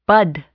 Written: bud

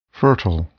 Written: fertile

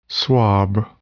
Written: swab

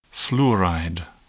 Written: fluoride

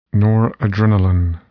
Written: noradrenaline

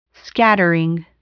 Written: scattering

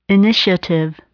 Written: initiative

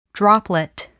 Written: droplet

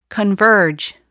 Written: converge

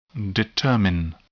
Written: determine

